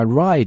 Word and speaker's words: wry: 这个地方注意一下 wry 这个单词的发音啊，就是 wry, 在这里 w 不发音，直接读 wry 就行了，叫 wry resignation。